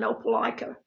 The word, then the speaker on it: Opelika: Now, I understand that you are from Opelika. Am I pronouncing that correctly? – You Opelika? pronounced it perfectly.